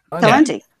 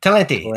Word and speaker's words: Talenti: It looks like talent with an I on the end. That's the only way I can say it. – Talenti. – Talenti. That sounds good.